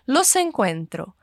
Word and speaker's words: los encuentro: Eu quero chamar a sua atenção para a pronúncia de duas palavras que soam como se fosse uma só. Los encuentro. Isso acontece porque los termina em consoante, em S. E encuentro começa com uma vogal, E.